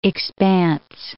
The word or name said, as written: expanse